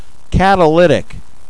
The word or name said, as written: catalytic